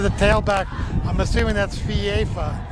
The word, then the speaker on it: FIFA: It is pronounced FIFA. Two syllables, FIFA.